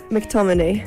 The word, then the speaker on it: McTominay: McTominay.